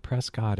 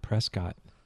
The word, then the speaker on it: Prescott: But can you share a little bit about what you do at Prescott and maybe a little bit about Prescott? – Well, first of all, it's pronounced Prescott. – Prescott. – Yeah, Prescott. Yeah. Like biscuit.